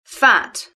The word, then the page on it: fat: British English